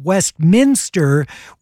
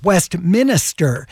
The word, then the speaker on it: Westminster: It's for the Orange County city of Westminster, which people mispronounce as Westminster.